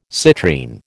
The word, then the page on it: citrine: American English